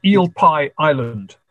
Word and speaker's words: Eel Pie Island: Now, the El Pai, I never was in it, but I've been by it. Did I pronounce that right? – No. It's Eel Pie Island.